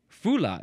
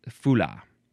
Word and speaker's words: Fula: Fula. Fula is next. I think that's how it's pronounced. F-U-L-A with a capital F.